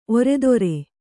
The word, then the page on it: ಒರೆದೊರೆ: oredore